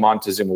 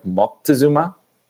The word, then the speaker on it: Moctezuma: Montezuma, the name Montezuma, which in the Aztec language is pronounced with a C instead of the N, so it's more like Moctezuma.